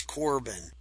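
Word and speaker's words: Korban: And they have a number of different names, but one name in particular that sticks out in most ancient writings is Corban. And it's Corban and Corban and Corban with a K. And it's spelled a lot of different ways, but it almost always comes out to that sound of Corban.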